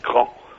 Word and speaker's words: Craon: Cran, ça s'écrit C-R-A-O-N. Ça s'écrit Craon et ça se prononce Cran.